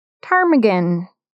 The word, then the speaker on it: ptarmigan: Today's bod is called the rock ptarmigan with a silent P. You decide where the P is. – Yep. – No, it's at the front.